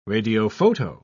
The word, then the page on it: radiophoto: British English